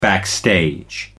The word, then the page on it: backstage: American English